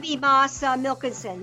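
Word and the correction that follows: Mads Mikkelsen: Am I saying his name wrong? Isn't he from Copenhagen? – I don't know. – Isn't it Moss? All right. You know, another round, that actor. Moss Mikkelsen.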